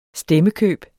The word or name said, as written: stemmekøb